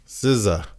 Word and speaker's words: SZA: You ever heard of SZA? – SZA. – Yeah. It's like spelled like RZA, but with the S.